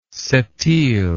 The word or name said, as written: septiles